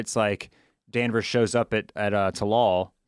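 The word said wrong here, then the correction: Tsalal: Am I saying that right? – Salal.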